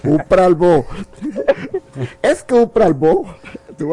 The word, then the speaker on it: bo: Bo. B-O. – Bo. Un pralbo. bo. Ajá. ¿Bo o bu, maestro? Bo, bo, B-O nada más.